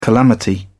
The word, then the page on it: calamity: British English